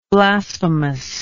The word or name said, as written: blasphemousness